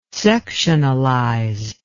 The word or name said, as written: sectionalize